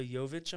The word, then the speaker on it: Jovic: Nikola Jovic, I'm pretty sure is how you say his name. He's an 18-year-old. – I'm just trying to say Jokic, but it's a V instead of a K.